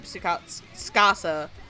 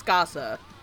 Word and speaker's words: Tsukasa: So when he's not, you know, attempting to rape Tsukasa, it's not Tsukasa, it's Tsukasa.